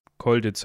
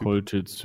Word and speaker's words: Choltitz: Äh, Dietrich von Koll Ditz, Koltitz, C-H-O-L-T-I-T-Z. 25. August. Kolditz heißt der, wird ja, glaube ich, ausgesprochen, der Typ. Kolditz, ja, hätte ich jetzt auch gesagt.